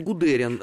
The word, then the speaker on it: Гудериан: А, на самом деле он Гудериан. А, я помню первое издание его воспоминаний, где специально в предисловии было указано, что он прусак, а не армянин, поэтому ударение там должно делаться вот так.